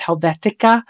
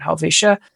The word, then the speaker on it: Helvetia: I've heard it pronounced Helvetica, which there's no C in the name, but I've seen and heard people call it that, but we call it Helvetia.